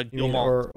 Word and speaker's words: Guillemot: No, Guillemot. – Who? – The CEO guy. – Oh, what's his name? Oh, yeah, Guillemot? – Yeah.